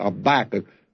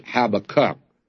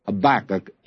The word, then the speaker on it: Habakkuk: So I'm going to beat you to it here with Habakkuk. Don't write in and tell me that it should be pronounced Habakkuk, because actually, that is not the better one, or I should say, the generally accepted one.